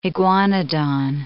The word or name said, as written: iguanodon